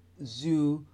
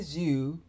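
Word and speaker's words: Xu: Kenny Zhu, I guess that's how you pronounce it, X-U,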